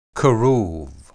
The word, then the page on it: kerub: Hebrew